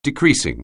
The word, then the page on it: decreasing: anglais